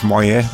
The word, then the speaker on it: Moyet: Jawel, Alison Moyet, of Moyet, mag je geloof ik ook zeggen.